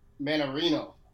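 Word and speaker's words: Mannarino: And so we still have Struve and Marinino. Manarino. – Make sure I say it. Say his name right.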